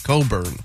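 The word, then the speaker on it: Cockburn: It is spelled... C word burn, like for a fella. – Oh. – So that's how it's spelled. But you pronounce it Coburn.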